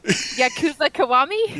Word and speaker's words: Yakuza Kiwami: Yakuza Kiwami? – Yeah.